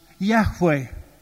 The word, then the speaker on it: Yahweh: Lord, with four capital letters, is the divine name, the name which is pronounced Yahweh.